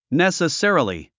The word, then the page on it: necessarily: nèsəsérəli; ネサセラリィ